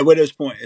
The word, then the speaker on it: Widow's Point: Yeah, Widow's Point.